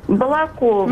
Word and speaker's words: Балакова: Простите меня, пожалуйста, за ударение. Балакова? – Балакова, ну, простите.